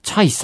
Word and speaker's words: CHAY: 続きまして、今度はチャイさんっていう人、皆さんご存知ですかね。多分有名だと思うんですけど、 CHAY と書いてチャイさんと読むらしいんですが、